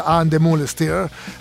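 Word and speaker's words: Ann Demeulemeester: Sì, ma ha visto nel weekend che Josh Stone a Parigi ha sfilato per questo stilista And the Moonstir, adesso spero di averlo pronunciato uno stilista belga. – Sì, no, l'hai detto giusto, ma ha fatto un po' strano, lui era...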